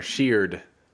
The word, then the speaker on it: Sheard: There's an R in there, Sheard.